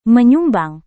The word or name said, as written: menyumbang